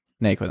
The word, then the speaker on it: Naquin: Naquin? How do we pronounce this dude's last name? – I always forget how to pronounce his last name. – Naquin, that's what I thought.